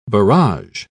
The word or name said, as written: barrage